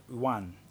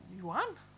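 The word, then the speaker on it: Yuen: His name is Wu Ping Yuan. – Yuan. – It's Y-U-E-N. – Yuan? – Yuan.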